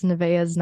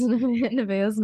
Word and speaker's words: Nevaeh: It's Nevaeh's Nightmare.